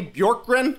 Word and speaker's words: Bjorkgren: Skeets, pronounce this name for me, Bjorkgren? – Yeah, that's pretty good.